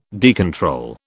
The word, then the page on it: decontrol: British English